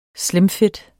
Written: slimfit

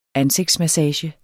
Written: ansigtsmassage

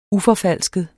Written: uforfalsket

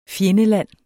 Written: fjendeland

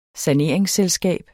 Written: saneringsselskab

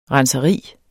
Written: renseri

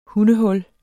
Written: hundehul

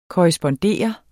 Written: korrespondere

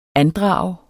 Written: andrage

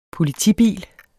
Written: politibil

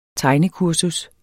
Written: tegnekursus

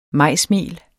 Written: majsmel

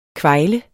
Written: kvejle

